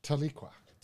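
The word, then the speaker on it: Tahlequah: Tequila. Wait. – Tequila? – I don't know if I've said that correctly. – Tequila? – How would you say that? – Taliqua. – Taliqua.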